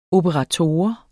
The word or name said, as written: operator